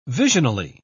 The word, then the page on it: visionally: British English